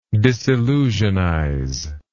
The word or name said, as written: disillusionist